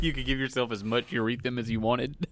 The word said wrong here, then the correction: Ethereum: Oh, Ethereum. – Or ether, whatever it is. Ethereum. I don't know how to say the word. – It's Ethereum. It's not a urinary tract.